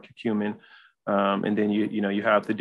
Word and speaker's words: curcumin: curcumin.